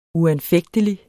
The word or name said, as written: uanfægtelig